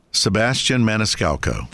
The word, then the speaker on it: Sebastian Maniscalco: And how do you say his name? – Sebastian Maniscalco.